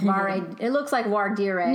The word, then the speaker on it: voir dire: It's a process called voir dire. – Mm-hmm. – I don't know if I think I'm saying that right. It looks like voir dire. – No, I think it's correct.